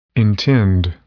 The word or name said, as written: intend